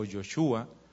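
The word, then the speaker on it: Yehoshua: Si hablamos en relación a Josué, en primer lugar, el nombre de Josué, que viene del hebreo, se pronuncia más o menos Joshua o Yoshua, significa Jehová es salvación.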